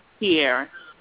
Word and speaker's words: Tiara: Tierra. – Spelling? – Okay, I was calling to T-I-A-R-A.